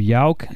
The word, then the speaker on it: Yauch: The Beastie Boy, Adam Yauch.